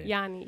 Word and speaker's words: Giannis: Yeah. Yanni, yes. Yeah. It's like Y-A-N-N-I.